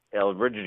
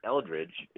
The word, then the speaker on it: Elbridge: And you said it right. It's not Eldridge. It's Elbridge, which is odd.